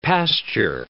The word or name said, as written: pasture